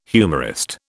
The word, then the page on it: humorist: British English